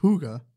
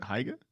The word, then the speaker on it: Higa: The language is the same as like the language that this place for the name for this place, Higa, which is pronounced Huga. – Huga. – Is it Higa? – No, it's Huga.